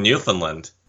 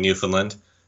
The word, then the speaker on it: Newfoundland: saying Newfoundland, when it's really Newfoundland. You really gotta emphasize the ending.